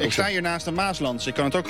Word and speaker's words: Maasland: Maasland. – Dus de klemtoon op Maas.